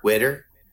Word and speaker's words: water: Yeah, if you say it like W-I-T-T-U-R, witter. – Witter. Witter. – Yeah, there you go. That's it. You did good.